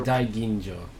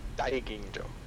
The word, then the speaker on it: Daiginjo: Aiginjo. I probably butchered that. – Junmai Super... Daiginjo. No, you're pretty accurate. – Daiginjo.